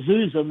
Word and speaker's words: zhuzh: What does Zuza mean? – The Bing translation thing, I think that it's saying that it's pronounced Zuz or something along those lines. – I can barely spell it. Z-H-U-Z-H? – Yeah, that's right.